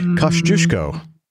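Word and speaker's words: Kościuszko: Kosciuszko is the way that you pronounce this.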